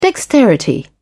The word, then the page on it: dexterity: British English